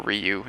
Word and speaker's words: Ryu: And playing Ryu or Ryu, if you want to say it correctly, uh, it kind of felt like it felt really good.